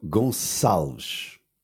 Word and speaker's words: Gonçalves: And it was, so then I can pronounce it, of course, Goncalves. – So, Gonçalves. – Gonçalves. – Yeah. With a S and a Sh at the end.